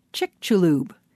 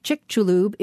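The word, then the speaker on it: Chicxulub: Chick-chu-lub.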